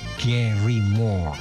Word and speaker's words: Gary Moore: Gary Moore, iya bacanya gitu.